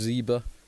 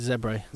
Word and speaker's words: Zebre: Then Southern Kings host Zebre. This will be a good leveller. – Yeah. – Zebre. Apparently we're saying it wrong. It's Zebre.